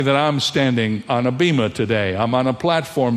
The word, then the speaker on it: bema: First of all, the word judgment seat is the translation of one little Greek word, which is pronounced bema, B-E-M-A.